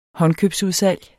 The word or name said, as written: håndkøbsudsalg